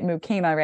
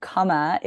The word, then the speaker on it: Kama: So, um, those are those are the the multiple definitions of, and then you were not wrong to pronounce it Mukema, right? Because kama is the proper pronunciation of that word.